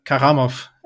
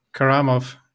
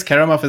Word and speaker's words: Karamov: It's all in the stress, so syllable stress. So it's Nikita Karamov, or Karamov. But I guess Karamov is actually way easier to say for the English ear.